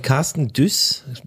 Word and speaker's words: Dusse: Und als Tagesempfehlung bringe ich heute, äh, passend zum Thema Vorsätze, ähm, etwas Achtsames, ein Buch mit ähm, von Carsten Düss, wird es glaube ich ausgesprochen, aber D-U-S-S-E geschrieben und zwar das Buch Achtsam Morden.